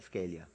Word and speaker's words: Scalia: Is it Scalia or Scalia? Scalia. Scalia. It appeared to him as deferring to like-minded foreigners.